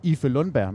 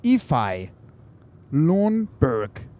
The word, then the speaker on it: Ife Lundberg: Så nu skal vi altså åbenbart ikke kalde ham Ife Lundberg, men han skal hedde Ify Lundberg. Det siger de, man skal sige.